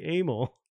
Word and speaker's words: Amell: Robbie Amell. I said that correctly.